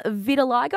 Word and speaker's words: vitiligo: vitiligo.